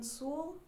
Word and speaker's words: Sewell: My name's Allison Sewell, S-E-W-E-L-L.